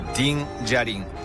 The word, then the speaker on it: ディン・ジャリン: で、ここの賞金稼ぎマンダロリアンことディン・ジャリン。これイントネーション合ってるディンジャリンで合ってます。